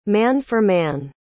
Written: man-for-man

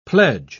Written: pledge

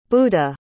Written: budha

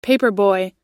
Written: paperboy

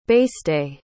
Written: beiste

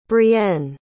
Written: brienne

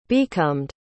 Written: becomed

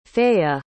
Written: feya

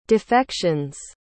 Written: defections

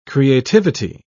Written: creativity

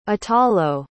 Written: atalo

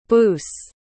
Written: boose

